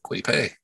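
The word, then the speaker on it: Kwity Paye: They're going with, let me see if I can say it right, Kwity-Paye. – Yep, Kwity-Paye. – Michigan. It sounds like what Pootie Tang says, Chris Rock's character.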